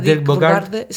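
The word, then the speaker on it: Dirk Bogarde: Y la cara de Bogart, ¿cómo se pronuncia? – Dirk Bogart.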